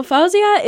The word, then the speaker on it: Fauzia: Jordan, I'd like to ask you to tell us about a little girl named Fauzia. Is that the right pronunciation? – It is, actually. I'm impressed that you got that right, actually. So Fauzia is a little girl that I met during my second trip to Ghana.